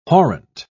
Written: horrent

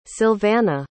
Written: Sylvana

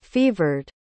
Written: fevered

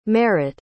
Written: merritt

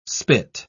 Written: spit

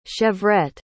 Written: chevrette

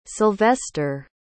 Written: Silvester

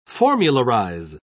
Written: formularize

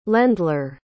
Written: landler

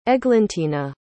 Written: eglantina

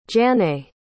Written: jannay

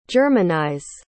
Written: germanize

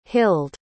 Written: hilde

